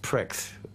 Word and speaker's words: Prix: He wanted to call us the pronounced pricks, P-R-I-X, that's what he meant.